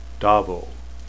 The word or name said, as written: dhavol